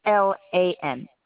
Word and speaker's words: Kaplan: Kaplan, like Kaplan University. Kaplan.